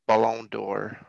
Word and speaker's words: Ballon d'Or: Ball d'Or? – Ballon d'Or. Ballon. It's golden ball in French.